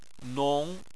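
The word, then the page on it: nong: tone 2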